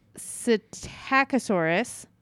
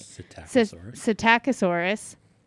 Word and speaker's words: Psittacosaurus: Psittacosaurus. Wait. Yeah, I said that right. – Psittacosaurus.